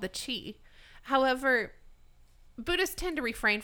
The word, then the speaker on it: qi: Typically it's pronounced chi, but it's spelled qi.